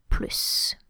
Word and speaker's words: plus: First of all, the mathematical sign, when you make an addition, is pronounced with the S, so it's plus.